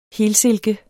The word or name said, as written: helsilke